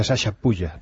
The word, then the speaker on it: Chachapoyas: Ya, pues se pronuncia Sasha Puya. Pero claro, si te digo eso vas a decir, no, hombre, lo estás diciendo mal, se dice chachapoyas.